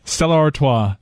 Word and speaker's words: Stella Artois: Stella Artois.